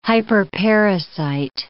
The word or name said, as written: hyperparasite